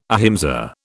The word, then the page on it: ahimsa: British English